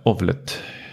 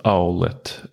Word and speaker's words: Owlet: Men eh, pulsen, syrenivån, sömnmönster och med den här lilla ovlet. Eh, jag tror det uttalas owlet, alltså som en uggla då.